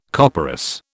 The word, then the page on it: copperas: British English